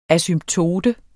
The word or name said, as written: asymptote